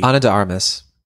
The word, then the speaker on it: Ana de Armas: Ana de Armas.